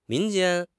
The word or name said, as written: 民间